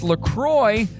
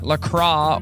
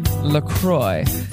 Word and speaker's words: LaCroix: Officially, it's pronounced LaCroix, so it rhymes with enjoy. LaCroix, not LaCroix or LaCray, like the French might say it. It's LaCroix. Jingle Bells was originally written to be a Thanksgiving song, not a Christmas song.